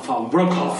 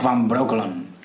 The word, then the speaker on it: Van Breukelen: Hans van Breukelen Van Breukelen. creo que se pronunciaba Van Broekelen. Van Broekelen. Sí, sí.